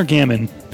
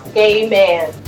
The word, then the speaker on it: Gaiman: Gaiman or Gammon? Who's Gaiman. – Your pronunciation. – It's officially pronounced Gay Man.